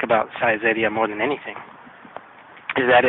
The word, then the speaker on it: Saizeriya: But the way I remember how to say it is that it sounds a lot like size area.